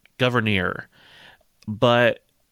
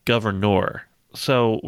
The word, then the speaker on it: Gouverneur: And Abigail Adams, who apparently, you know, was an expert, says, yeah, his first name is pronounced Gouverneur, but there's this city that he, or maybe like a little town, that he helped found, and they pronounce the city's name Gouverneur. So we're just going to, we'll probably interchange it at points here throughout the episode and call him different things.